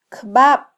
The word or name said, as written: kbab